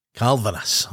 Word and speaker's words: Kalvenes: Is it Kalvenes? What is the pronunciation of your name? – The right one is Kalvenes. – Kalvenes. Okay.